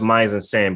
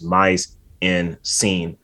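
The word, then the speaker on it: mise en scène: It's pronounced Mizen Sen, but the way it looks is Mize in Scene.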